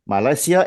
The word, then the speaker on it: Malasia: So it is my pleasure to introduce you to the guys from Malaysia FC. Um, did I pronounce that correctly, guys? Yes, yes, it's. it's okay.